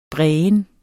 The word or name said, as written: brægen